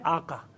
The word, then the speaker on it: آقا: یہ ایسا نہیں ہے, یہ ہے آقا. لیکن کیونکہ ان کا زبان کا جو گلے کا جو تلفظ وہ نکلتا ہے آغا, تو کہتے ہیں آغا تشریف لائے, آغا بیٹھیے, وہ لفظ ہے آقا.